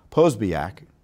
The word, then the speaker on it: Posobiec: Jack Posobiec, I keep on mispronouncing his name. Posobiec.